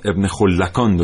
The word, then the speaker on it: ابن خلکان: متشکرم خانم موسوی من یه اصلاحی بکنم ابن خلکان درسته ما خودمونم نمیدونستیم این اعلام بعضی وقتا خیلی مشکل ساز میشن پیش از برنامه توانستیم با یکی از متخصصین تماس بگیریم از ایشون بپرسیم تلفظ صحیح ابن خلکانه بله.